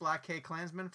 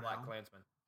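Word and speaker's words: BlacKkKlansman: I didn't think it was actually meant to be like pronounced. I think it's just Black Klansman.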